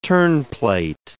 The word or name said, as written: terneplate